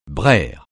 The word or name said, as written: braire